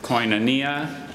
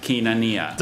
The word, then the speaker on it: koinonia: There's koinonia and kinonia.